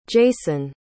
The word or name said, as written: jasone